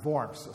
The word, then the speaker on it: Worms: But it's in German it's pronounced Worms, so that's what I do, just so we don't get into the Worms business.